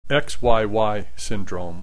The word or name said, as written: XYY syndrome